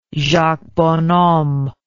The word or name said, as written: Jacques Bonhomme